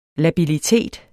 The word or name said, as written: labilitet